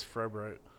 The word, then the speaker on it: Froehbrodt: It's Froehbrodt.